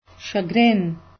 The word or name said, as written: chagrin